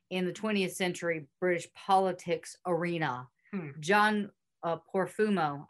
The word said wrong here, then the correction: Profumo: I think that's how you say it, Porfumo. We're going to go with Profumo.